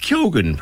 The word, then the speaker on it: Keoghan: It is Keoghan, apparently. He pronounces the G.